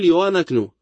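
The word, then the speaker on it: Loanaknu (לא אנחנו): Qual é a diferença sonora entre Loanaknu e Loanaknu? Diga-me qual é qual. Você não pode. É pronunciado da mesma maneira. É Loanaknu.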